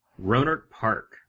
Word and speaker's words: Rohnert Park: Rohnert Park.